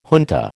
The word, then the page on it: junta: American English